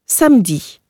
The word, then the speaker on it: samedi: Als unbetonter Auslautvokal einer Silbe verstummt das E allerdings oftmals ganz, zum Beispiel in samedi, Samstag.